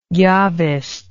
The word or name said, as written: Jahvist